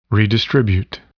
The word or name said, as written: redistribute